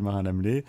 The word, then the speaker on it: Marin-Lamellet: Rémi Michel Marin-Lamelé.